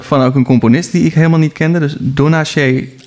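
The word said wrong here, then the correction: Donnacha: Donneke. – Donneke, Donneke oh, sorry. Zo moet uitspreken.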